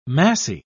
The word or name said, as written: massy